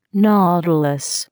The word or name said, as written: nautilus